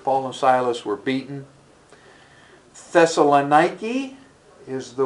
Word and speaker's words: Thessalonica: And so it's kind of fun when our tour guides are talking and you hear, it's not Thessalonica, it's Thessalonica. And that's the way they'll pronounce it.